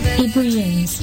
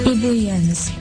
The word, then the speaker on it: ebullience: Ebullience, ebullience, สะกด e b u l l i e n c e ebullience นะคะหรือนะคะคำนี้มีอีกนะคะสามารถที่จะใช้อีกหนึ่งคำก็คือคำว่า ebullience